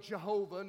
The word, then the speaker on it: Yahweh: We're not sure how to pronounce it because the Jews would never speak the holy name. So the old way, Jehovah, now we think probably Yahweh.